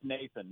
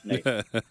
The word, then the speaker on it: Nathan: Oh, it's Nathan. No, man. If you're mad at me, you call me Nathaniel. Otherwise, you leave those extra syllables off. It's just Nathan.